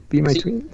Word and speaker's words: BEMYTWYN: los chicos del proyecto de Be My Twin, ¿lo dije bien? Sí, Be My Twin, sí.